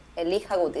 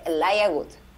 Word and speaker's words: Elijah Wood: Elija, se, se escribe Elija con E-L-I J-A-H, Good, W-O-O-D. Elija Good y se dice Elia Good.